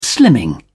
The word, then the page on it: slimming: British English